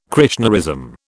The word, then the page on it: Krishnaism: British English